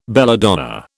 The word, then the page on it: belladonna: British English